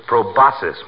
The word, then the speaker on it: proboscis: It's proboscis, my boy. The C is silent, like a husband in a millinery shop.